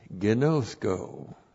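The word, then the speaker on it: Gnosko (γινώσκω): And then gnosko. G-I-N long O-S-K long O. Gnosko.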